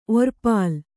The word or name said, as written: ಒರ್ಪಲ